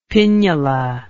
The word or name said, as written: pinnula